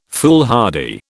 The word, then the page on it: foolhardy: British English